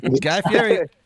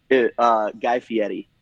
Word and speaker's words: Guy Fieri: Do you say Guy or you say Guy? – Guy Fieri. – They were very specific with us when we filmed on how to pronounce his name. – Oh, yeah. – We wanted to make sure. Yeah. – What did he say? – Guy Fieri. – Yeah. Fieri.